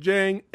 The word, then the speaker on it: Djeng: Frank Djeng in the house. That's right. – The D is silent. – That's right. The D is silent. Yes.